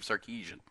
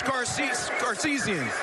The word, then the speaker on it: Sarkisian: Let's grade Brett Yormark's pronunciation of Steve Sarkisian. – It's an honor to give Coach Garcisian and the Texas Longhorns the Dr. Pepper Big 12 championship. Coach Garcisian.